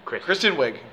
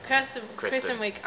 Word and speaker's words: Kristen Wiig: Kirsten Wiig has a podcast? – Are we? – Kristen Wiig. – Kirsten Wiig.